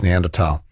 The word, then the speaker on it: Neanderthal: I hate to correct Kelvin, but as someone who has a postgraduate degree in German, I have to tell him that the H in Neanderthal is in fact silent and not pronounced. So you, I think Kelvin's saying that in English you can sort of say Neanderthal. Can you? I'm not sure what the convention is actually, but yeah. If you're being strict to the German, it's Neanderthal.